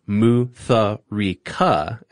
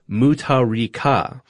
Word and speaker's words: Mutharika: H's are often used to denote emphasis and are silent in most cases. Hmm. Mutharika is not pronounced Mutharika, as y'all were pronouncing it, but is actually pronounced Mutharika.